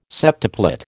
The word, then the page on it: septuplet: American English